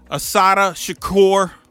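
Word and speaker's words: Assata Shakur: Asada Shakur.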